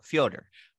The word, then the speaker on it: Fyodor: I think it's like two syllables. Fyodor.